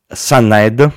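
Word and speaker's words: Sannahed: Dovrebbe stare persino dentro alla cornice quadrata Sunnaed, si pronuncia così, di Ikea, e costa una cifra ragionevole, 60 euro.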